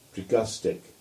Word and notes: Tregustick: English

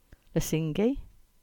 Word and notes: Lesingey: English

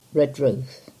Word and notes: Redruth: English

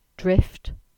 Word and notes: Drift: English

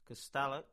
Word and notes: Castallack: English

